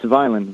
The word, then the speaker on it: Vineland: Did I say that right? – It's Vineland.